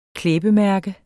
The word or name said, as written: klæbemærke